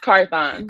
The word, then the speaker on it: Carthon: It's Carthon.